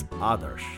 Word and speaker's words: Adarsh: It's Adarsh. That's like other with an A and ending with an SH. Adarsh Vijay Mudgill.